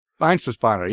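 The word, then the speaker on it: Weihenstephaner: Well, how do you say that? – Weinstephaner, yes.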